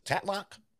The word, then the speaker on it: Tatlock: I think the one thing I didn't know much about even going back, but Florence Pugh's portrayal of Jean, is it Tatlock? – Yes.